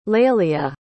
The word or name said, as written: Laelia